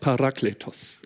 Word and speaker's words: Parakletos: ist das griechische Wort Parakletos. Ungefähr so wird es, nicht ganz, aber so ungefähr wird es ausgesprochen.